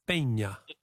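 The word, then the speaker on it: Peña: Marco Pena, with a little accent on the N, Pena.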